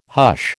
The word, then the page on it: hush: American English